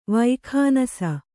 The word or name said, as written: ವೈಖಾನಸ